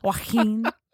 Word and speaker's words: Joaquin: Joaquin. – That's how you say it. – Joaquin.